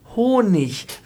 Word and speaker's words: Honig: Es ist nämlich nicht ich, sondern äh, am Wortende im Hochdeutschen immer ein Ich, also König, Honig, wenig.